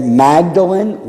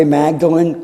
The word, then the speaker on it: Magdalen: And then in 1924, he is elected a fellow and tutor in English at what is called, we would see it read Magdalen, like Mary Magdalene College at Oxford, but it's pronounced Magdalen. Just a British thing.